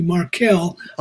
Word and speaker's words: Markell: Yeah, the pronunciation is actually Markell. Okay. I even called their office. I called their office to make sure I was saying it right.